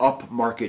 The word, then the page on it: upmarket: American English